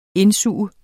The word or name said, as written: indsuge